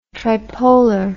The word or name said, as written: tripolar